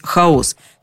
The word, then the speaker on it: хаос: Уже в XIX веке ударение переместилось. В этом значении все начали говорить «хаос», как будто бы специально, чтобы подчеркнуть – это не тот хаос, про который думали и говорили древние. Это проще, ближе к нам, грешным.